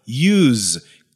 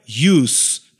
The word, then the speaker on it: use: que daí a pronúncia será use, que significará usar. Porém, aqui, como eu disse antes, eu estou utilizando ela como um substantivo, e a pronúncia do substantivo uso é use, tem som de S, não de Z, ok?